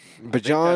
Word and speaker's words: Bijan: Bijan.